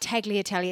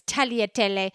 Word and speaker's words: tagliatelle: And then there's a G in there. – Yeah. It's a silent G. And the server corrected, when I corrected our friend Steen, and I said, it's not tagliatelle, it's tagliatelle.